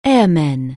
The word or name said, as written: airman